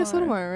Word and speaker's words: Sotomayor: I think, like, the liberal justices, like, I always say her name wrong. It's not Sotomayor, right? It's, like, is this Sotomayor? – Yeah, Sonia Sotomayor, right?